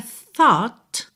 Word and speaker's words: thought: A palavra thought, que é o passado de think, pensar, se escreve T-H-O-U-G-H-T, T-H-O-U-G-H-T, mas o G-H não é pronunciado, tá? Fica totalmente silencioso.